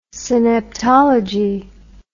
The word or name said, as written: synaptology